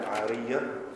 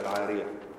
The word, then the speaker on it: العارية: والعارية تنطق بتشديد الياء العارية أو بتخفيف الياء العارية وهي اسم لما يعار يعني يعار لأحد الناس كأن يكون هناك معير ومستعير وشيء معار.